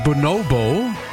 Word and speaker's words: Bonobo: Ihr hört 1 Live Plan B, jetzt mit Bonobo bzw. Bonobo, wie er ausgesprochen wird.